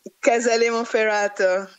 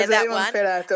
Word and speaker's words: Casale Monferrato: Casale Monferrato. – Yeah, Casale Monferrato.